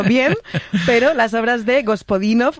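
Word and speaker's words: Georgi Gospodinov (Георги Господинов): Ayúdanos a pronunciar los autores. – A ver, Georgi Gospodinov, sí. Zdravka Eftimova y Vera Mutavcheva.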